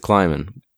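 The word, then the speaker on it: Klieman: Is that how you pronounce it? Kleiman? – I don't know. I think Kleiman so. Or Kleiman? – I think it's Kleiman.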